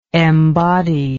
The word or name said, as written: embodier